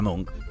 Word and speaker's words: Munch: Però s'escriu Munch, no?, en el fondo, i se pronuncia Munch.